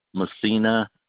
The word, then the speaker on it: Messina: Pasquale, P-A-S-Q-U-A-L-E, Messina, M-E-S-S-I-N-A.